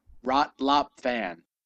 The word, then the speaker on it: Rot Lop Fan: Rot Top Flan, the F sharp bell. – Rot Lop Fan.